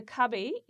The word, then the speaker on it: Kubbi: The Cubby is spelt K-U-B-B-I, and that is an Aboriginal name for possums.